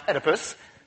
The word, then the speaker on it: Oedipus: Oedipus, pronounced Oedipus, solved the riddle by answering this.